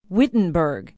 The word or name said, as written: wittenberg